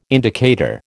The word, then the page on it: indicator: American English